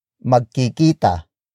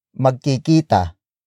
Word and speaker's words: magkikita: Up next is magkikita. Magkikita. Magkikita. Magkikita is spelled as M-A-G-K-I-K-I-T-A.